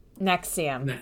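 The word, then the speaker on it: NXIVM: Nexium.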